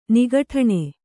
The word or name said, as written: ನಿಗಠಣೆ